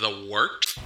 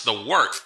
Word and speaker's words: The Wort: It is The Wort, not warts, not fort, not short, The Wort.